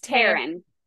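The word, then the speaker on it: Tairn: How do you say his name? – It's Taryn. – Taryn. – I said Taryn. – I think I said Taryn. – It's the audiobook says Taryn.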